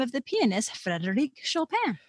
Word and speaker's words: Chopin: So don't say the N, it's an. – Okay. – Chopin. And I know that's hard for non-native speakers to make that sound, but that's the sound.